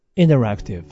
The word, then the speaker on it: interactive: Interactive。 T を発音してくれないんですね。 Interactive。